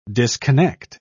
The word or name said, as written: disconnect